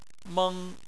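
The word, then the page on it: meng1: tone 1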